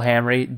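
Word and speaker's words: Hamri: It's J.L. Hamry, J.L.H.A.M.R.I.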